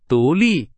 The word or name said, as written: 独立